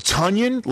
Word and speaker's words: Tonyan: It's Tonyan, like rhymes with... – Rhymes with Funyan. – Did Funyan. The Packers PR actually use that as the example? – Yes, they did. – Funyan. – Right, like Funyuns.